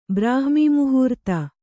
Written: ಬ್ರಾಹ್ಮೀಮುಹೂರ್ತ

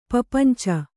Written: ಪ್ರಪಂಚು

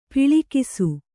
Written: ಪೊಳಕಿಸು

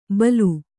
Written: ಬಲು

